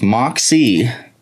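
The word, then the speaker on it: Moxee: It's like two syllables. Moxie. It's M-O-X-E-E. So you say like this. Pay attention. Everybody gets it wrong.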